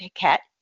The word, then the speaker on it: Heket: They had a goddess named Heket. It looks like it's H-E-Q-E-T, but it's spelt more like Heket.